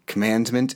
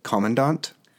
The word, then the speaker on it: commandant: saying like commandment instead of commandant. like it was in a military sci-fi thing and one of the people was a commandant and the narrator didn't know how to pronounce the word correctly and i don't oh, remember God.